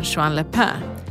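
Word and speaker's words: Juan-les-Pins: Var det okej okay uttal på jean Le Pen? Det var helt perfekt.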